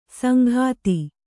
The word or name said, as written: ಸಂಘಾತಿ